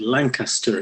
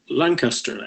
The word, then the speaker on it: Lancaster: And the first thing I picked up was that although all the official transcripts say Tippit's last call was at Lancaster at 8th, note that Lancaster in English as well as US English is three syllables, Tippit actually said Lansing at 8th.